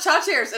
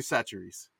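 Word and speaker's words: Chachere's: We always called it Chachers in my family. – I almost want to do the Louisiana pronunciation game if she pulled the Tony Chacha. – I know some of it. I have an aunt who lives in New Iberia, but I don't know. – It's Tony Satcheries.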